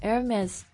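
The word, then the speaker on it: Hermès: 哎，但是那个爱马仕那个什么 Hermes，它那个 S 就是发音的呀。 – Yeah, that's kind of weird. I don't know why. It could be just that one exception. – 反正那东西那么贵，跟我一辈子也没关系。它标准的法语念法怎么念啊？爱马仕 Hermes。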